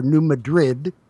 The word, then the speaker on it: New Madrid: to show how they would react during the next major earthquake along the New Madrid fault line, or New Madrid, however you may choose to pronounce that, which is a major source of earthquakes in the Midwest and the South of the U.S.